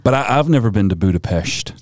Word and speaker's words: Budapest: So I have visited Budapest, but it's actually Budapest is how you say that.